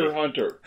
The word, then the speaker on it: Hunter x Hunter: Isn't that show just called Hunter Hunter? – It's called Probably. Hunter Hunter. – The X is silent. – They put an X there, but I'm going to say it anyway, partly because it annoys so much.